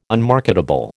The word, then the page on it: unmarketable: American English